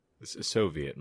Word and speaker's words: Soviet: Is is that not how you pronounce it? Soviet. – Soviet, my friend.